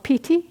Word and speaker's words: piti: And when that happens, then the third of these factors begins to become apparent, and it's called piti in Pali, P-I-T-I is how it's spelled, and it appears in many, many forms, but in my experience they all have a very energetic quality.